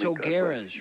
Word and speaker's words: O'Gara's: See you at O'Hara's. – All right, God bless. – Thanks, it's O'Gara's, Fred. – O'Gara. How do you spell it? O'Gara. – Yeah, O'Gara's. Don't look for O'Hara's.